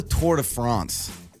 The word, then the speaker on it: Tour de France: That's the Tour de France.